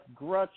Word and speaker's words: Groetsch: It's uh, it's Grutch. It's like crutch with a G. That's the the easiest way I always uh, compare it.